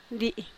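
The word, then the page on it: dii: Teotitlán del Valle Zapotec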